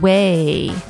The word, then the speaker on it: Ouai: And also we have Way, pronounced Way, but spelled O-U-A-I.